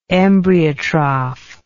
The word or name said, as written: embryotrophy